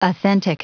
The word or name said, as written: authentic